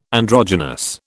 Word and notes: androgynous: British English

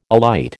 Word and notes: alight: American English

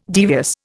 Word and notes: devious: Indian

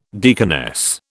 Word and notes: deaconess: British English

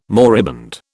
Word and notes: moribund: British English

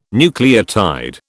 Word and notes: nucleotide: British English

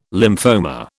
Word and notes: lymphoma: British English

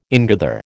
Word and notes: ingather: American English

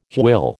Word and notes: hwyl: American English

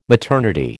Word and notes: maternity: American English

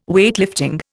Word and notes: Weightlifting: Indian

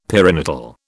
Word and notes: perinatal: British English

